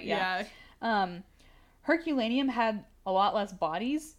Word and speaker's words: Herculaneum: Herculaneum, I'm hoping I pronounced that correct. – I've heard of that. That's I think that's correct.